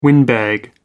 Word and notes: windbag: American English

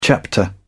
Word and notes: chapter: British English

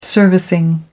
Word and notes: servicing: American English

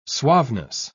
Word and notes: suaveness: British English

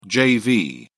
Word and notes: jv: British English

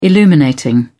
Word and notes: illuminating: British English

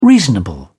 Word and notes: reasonable: British English

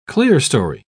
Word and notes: clerestory: British English

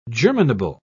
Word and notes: germinable: British English

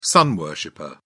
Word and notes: sun worshipper: British English

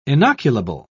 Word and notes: inoculable: British English

